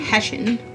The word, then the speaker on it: Hessian: He's the ghost of a, trying to say this properly, Hessian soldier? – What? – A Haitian soldier. No. Here. – Bless you. – Hessian. – That one.